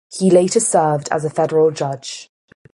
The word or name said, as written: Federal